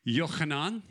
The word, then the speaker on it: Yochanan: Yochanan is how his name is pronounced.